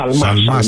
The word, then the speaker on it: Σαλμά: Να πούμε πολύ γρήγορα και για την άρση ασυλίας του κυρίου Σιαλμά, έτσι δεν είναι? – Σαλμά, συγγνώμη για το, το γιώτα που εμφυλοχώρησε στην προφορά μου.